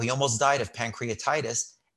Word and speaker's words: pancreatitis: Almost died from pancreatitis.